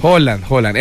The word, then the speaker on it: Haaland: Pero las mujeres, estoy notando, es que extrañan del mundial no los partidos, sino a Haaland. O bueno, ¿cómo se dice? – Haaland.